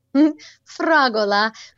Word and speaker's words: fragola: How do you say strawberry in Italian? – Fragola.